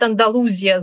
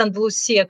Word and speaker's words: Андалусия: И на самом деле, если мы говорим об э, Андалусии, то некоторые люди говорят Андалузия с буквой, со звуком «з», другие говорят «А, Андалусия с «с», а другие, третьи говорят Андалусия, как я, потому что это правильное ударение именно в испанском языке.